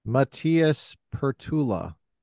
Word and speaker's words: Matias Perttula: Today we have a very special guest, Matias Perttula.